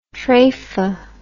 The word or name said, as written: trefah